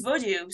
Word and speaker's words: Vodou: And most of the time when I talk about my research, people think that I mispronounce it because of my accent. You know, I come from France, I probably don't know how to say it, but it's actually not the case. It is Vodou.